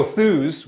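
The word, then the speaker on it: Thuuz: Oh, so Thews, which is spelled T-H...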